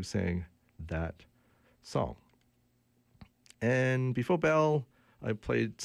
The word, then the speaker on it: Behl: Before that, an old, old band from Winnipeg, and I do know how to pronounce them. It's called Bell. It's spelled B-E-H-L.